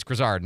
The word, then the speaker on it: Grizzard: It's Grizzard, not Grizzard. I believe that's correct.